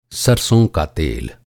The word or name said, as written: सरसों